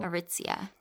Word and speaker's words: Aritzia: This reminds me of like Aristia. Was that what it was called? – Aritzia. – Thank you.